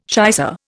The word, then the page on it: shicer: Indian